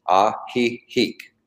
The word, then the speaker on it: Ajijic: It's Ajijic, but the J's are pronounced like H's. Ajijic.